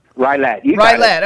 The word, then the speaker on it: Rylett: Rylett.